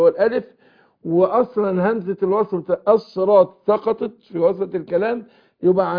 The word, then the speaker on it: الصراط: فقول اهدنا الصي الصي الصي، يبقى الصاد حرف صفير وبين الكسر كسره، لكن اياك وانت تنطقها سين. اقول الصراط، لا الصي، شوف حرف الصفير اهو، الصراط الطل مستقيم طل.